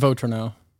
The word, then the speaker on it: Votrenot: Votrenot. – There's no... The T is silent. – The T is there, but it's silent.